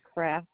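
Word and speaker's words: Kraefft: Catherine, K-A-T-H-R-Y-N. Last name is Kraft, and it's spelled K-R-A-E as in Edward, F as in Frank, F again like Frank, T like Tom.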